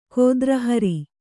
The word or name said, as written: ಗೋತ್ರಹರ